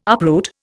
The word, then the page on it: uproot: Indian